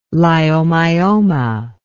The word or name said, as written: leiomyoma